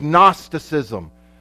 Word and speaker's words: Gnosticism: The third major heresy that primarily the apostle John dealt with was the heresy of Gnosticism. And it's not spelled with an N, it's spelled with a G. Yeah, because it's out of a Greek word that means gnosis, which means to know.